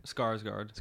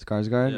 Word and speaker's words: Skarsgård: I don't know how to pronounce pronounce his last name, but I'm gonna pick Alexander Skarsgård. – Skarsgård. Skarsgård, yeah.